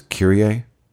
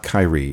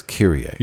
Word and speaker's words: Kyrie: The proper way to pronounce that word is Kyrie. Huh. It's Latin. It's not Kyrie. It's Kyrie.